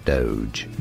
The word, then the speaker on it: Doge: We are looking at how to pronounce the name of this cryptocurrency. How do you say it? Yes, it is said as Doge. – Doge. – Doge. Doge. Therefore, Dogecoin.